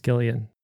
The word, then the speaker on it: Gillian: Jillian Anderson, but Jillian Welch is actually pronounced Gillian with a hard G.